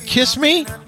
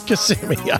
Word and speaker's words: Kissimmee: I guess I don't know how to say this word. Kiss me? Kiss me? What is it? – Kissimmee.